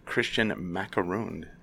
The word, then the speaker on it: Kristian Macaron: Author of the book Recipe for Time Travel in Case We Lose Each Other, Kristian Macaron.